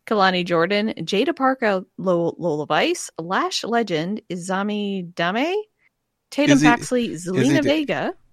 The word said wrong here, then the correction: Izzy Dame: Izzy Dame.